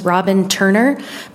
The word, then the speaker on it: Robin Turner: My name is Robin Turner. My name is spelled R-O-B-I-N-T-U-R-N-E-R, and I am here with the American Civil Liberties Union of the State of Montana rising in opposition to Senate Bill 284.